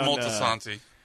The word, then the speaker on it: Moltisanti: Is that how you pronounce it? – Yeah, Christopher Moltisanti.